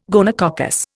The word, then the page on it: gonococcus: Indian